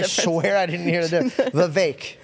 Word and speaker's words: Vivek: Vivek rhymes with cake. – I do not hear the difference that you're saying. – Vivek. – You're saying Vivek. – Okay. – It's Vivek.